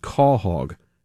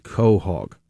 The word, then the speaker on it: quahog: Number one, you listened, you learned it's properly pronounced quahog, not quahog.